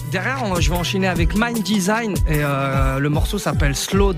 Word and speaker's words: MNDSGN: Mind Design. Okay. Mind Design s'écrit M-N-D-S-G-N.